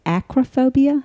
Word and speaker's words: acrophobia: So that is acrophobia, I believe I'm pronouncing that correctly. – It is, yes.